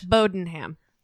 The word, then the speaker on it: Bowdoinham: Bowdenham.